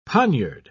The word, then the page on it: poniard: British English